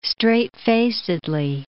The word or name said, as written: straight-facedly